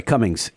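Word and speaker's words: Cummins: the G is silent. – Cummings, yes.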